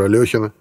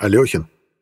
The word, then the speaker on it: Алехин: Раньше-то я думал, что фамилия гроссмейстера произносится как Алехин, пока в клубе шахматистов Одесского порта, куда я забрел в уже казавшиеся незапамятными времена, меня вежливо не поправили. Никаких «ё», Алехин через «е».